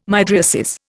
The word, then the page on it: mydriasis: Indian